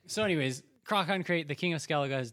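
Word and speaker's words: Crach an Craite: So as you arrive, the king of Skellige, Krachankrate, I'm just going to say it that way, I've checked my dictionary. That is exactly how you pronounce it.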